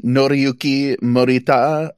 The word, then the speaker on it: Noriyuki Morita: Noriyuki Morita.